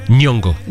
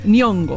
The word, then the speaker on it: Nyong'o: ¿Nihongo? – Nyong'o. – Nyong'o.